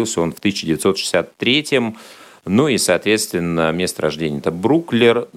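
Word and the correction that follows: Brooklyn: э, Бруклин, э, город, э, можно сказать, часть, район даже Нью-Йорка.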